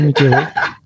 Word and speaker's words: Mickey Rourke: Fait que ça, dans le fond, il s'est fait passer pour un cinéaste qui aurait arnaqué plusieurs célébrités, dont l'acteur Mickey Rourke? Je ne sais pas comment le prononcer, là. – Ah oh, ouais, Mickey Rourke.